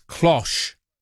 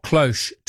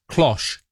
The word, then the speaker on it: cloche: No, Roman, it's cloche, not cloche, to rhyme with posh. I thought I had it right before when someone else corrected me. Cloche.